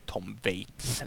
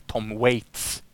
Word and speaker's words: Tom Waits: Och den gemensamma nämnaren här är ju Tom Waits, eller Tom Waits. – Precis. – Bättre ett uttal.